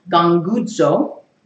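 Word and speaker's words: Gangudzo (γογγύζω): It's an onomatopoeia. It has, when you say the word in Greek, it actually sounds like it is. And maybe you'd like to try it. You can pronounce it like this. Gangudzo.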